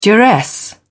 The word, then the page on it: duress: British English